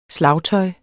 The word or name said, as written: slagtøj